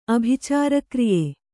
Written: ಅಭಿಚಾರಕ್ರಿಯೆ

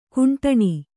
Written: ಖಂಡನೆ

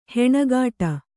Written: ಹಣೆಕಟ್ಟು

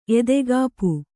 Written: ಎದೆಕಾಪು

